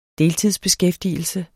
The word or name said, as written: deltidsbeskæftigelse